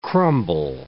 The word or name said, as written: crumble